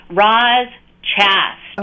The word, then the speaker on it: Roz Chast: And then there's Ruth Chast. I'm not familiar. – Let me pronounce it for you, because she is a kid. Roz Chast. – Okay. – It's her name.